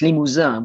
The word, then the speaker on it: Limousin: Limousin, if you pronounce it in English, but it's Limousin, which is the name of a forest.